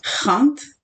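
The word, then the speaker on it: Gent: Je pense que ça se prononce comme ça en français, sinon en néerlandais, ça se prononce Rant.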